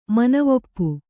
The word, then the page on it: ಮನವೊಪ್ಪು: kannada